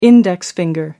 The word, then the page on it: index finger: American English